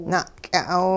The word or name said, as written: na'ky'a'aw